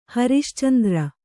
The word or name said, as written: ಹರಿಶ್ಚಂದ್ರ